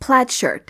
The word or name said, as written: plaid shirt